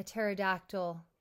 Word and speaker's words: pterodactyl: Um, why can you not hear a pterodactyl going to the bathroom? – Oh my goodness. – That's your dad joke. – That's my favorite dad joke. – Because the P is silent.